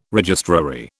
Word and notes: registrary: British English

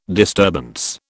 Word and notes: disturbance: British English

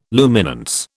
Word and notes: luminance: British English

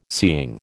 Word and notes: seeing: American English

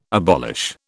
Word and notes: abolish: British English

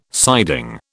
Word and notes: siding: British English

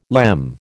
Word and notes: lemme: American English

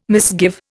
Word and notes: misgive: Indian

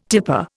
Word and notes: tipper: Indian

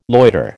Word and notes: loiter: American English

